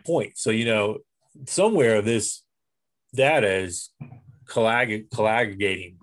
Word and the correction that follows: coagulating: you know, is it right? Did I say that right? – Coagulating? – Yeah, there you go.